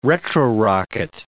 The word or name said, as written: retro-rocket